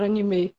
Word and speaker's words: anime: Então é mais comum pra gente falar anime do que falar anime. Eu acho que os mais otakus mesmo que, que prezam por essa, por essa forma de pronunciar o nome, né? Mas pra mim as duas formas estão corretas.